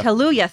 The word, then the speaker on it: Kaluuya: Kaluuya. – Kaluuya, thank you.